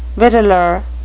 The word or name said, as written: victualer